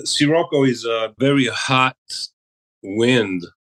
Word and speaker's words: Scirocco: One of the songs you sent us, I'm going to ask you to help me pronounce it. Is it Scirocco? – Scirocco.